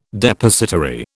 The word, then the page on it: Depositary: British English